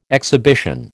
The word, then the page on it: exhibition: American English